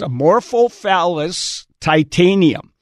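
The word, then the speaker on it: Amorphophallus titanum: It's Amorphophallus titanium.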